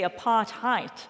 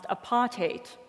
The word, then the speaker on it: apartheid: So often when I hear the word apartheid pronounced in America, you say apartheid because it's H-E-I-D, and that's how that Germanic word is pronounced here. But in that Germanic origin, it's actually pronounced apartheid, which sounds a lot more like the end is spelt H-A-T-E, right? Well,